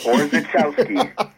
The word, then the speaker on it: Orzechowski: So originally he was Benjamin Orzechowski. Is that how you pronounce it? – That's correct. Orzechowski. Of Polish descent, obviously.